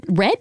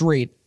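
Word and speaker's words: Redd: It's pronounced Reed. – Reed. – Reed. – Sorry. Looks like Red. – Yeah. – Is Reed. – Okay. Red. Yes, because it's R-E-D-D, but it's Reed.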